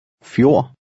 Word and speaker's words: Fjord: The D at the end is silent. – Fjord.